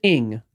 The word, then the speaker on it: Ng: Jeff with a G said, when you see the Asian last name of NG, you pronounce it as ing, as if there were an I there.